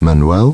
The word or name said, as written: Manuel